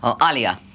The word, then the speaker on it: Alaya: Alia, you know, it's spelt Alaya, but Alia, who is Pooja's daughter, is going to be co-producing.